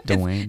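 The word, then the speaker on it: Dwynwen: Saint Dwayne Wednesday. – Saint Dwayne. – Dwayne Wednesday. – Dwayne. – It's spelled DWYNWENS.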